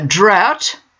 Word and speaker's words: drought: A drought is a period of no rain. Note the pronunciation, which rhymes with out.